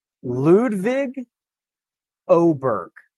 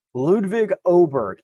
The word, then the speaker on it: Ludvig Åberg: One, I'm sitting next to a very nice gentleman from Swedish television, and he told me that the acceptable English pronunciation would be Ludvig Åberg. I can do that as well. So I'll have to get that into my brain. Ludvig Åberg is acceptable.